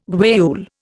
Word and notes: guayule: Indian

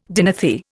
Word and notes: dinothere: Indian

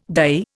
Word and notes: dye: Indian

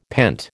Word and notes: pent: American English

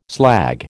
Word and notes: slag: American English

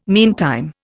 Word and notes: meantime: Indian

